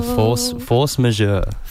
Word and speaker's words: Force Majeure: Yeah, Force Majeure.